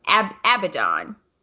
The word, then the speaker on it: Abaddon: Abaddon. Right? Yep. Am I saying it right? – Yeah.